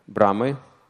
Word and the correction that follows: Брахма: Брам, Брахма, так пишется, Брахма.